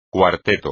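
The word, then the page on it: cuarteto: español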